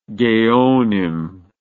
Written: geonic

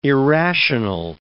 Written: irrational

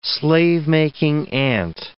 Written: slave-making ant

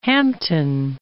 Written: Hampton

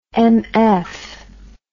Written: mf

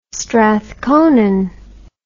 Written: strathconon

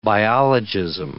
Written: biologism

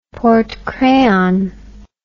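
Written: porte-crayon

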